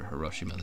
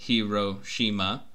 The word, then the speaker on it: Hiroshima: Anybody listening, you can go to the Wikipedia page for Hiroshima, the city. – Okay. It's Hiroshima. – So on the thing here, it shows the phonetics of it. For U.S., it says Hiroshima.